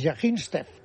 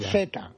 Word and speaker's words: Zvyagintsev: Es Yaginstev. ¿Cómo se pronuncia eso? – Creo que es buenísima. – Zvyagintsev.